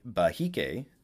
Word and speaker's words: Behike: Behike, meaning priest, shaman, or medicine man. It's pronounced Behike.